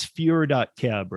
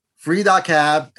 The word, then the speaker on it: fure.cab: Because if you guys go, it's fure.cab, right? – Free.cab, FURE.CAB. – Everybody, I just said fure.cab because that's how you'll spell it in your head. But yeah, yes, it's free.